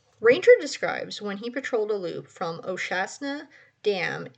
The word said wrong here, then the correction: O'Shaughnessy: in the, I think I'm pronouncing that wrong, Oshastnessy. Do you know that dam? – Is it O'Shaughnessy? – Yes, thank you.